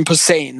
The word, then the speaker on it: Posehn: Brian Paulson. Sorry, not Brian Pollan. Gore filmmaker. – It's like a wow. – Look, Brian Posehn. That's how you say his name.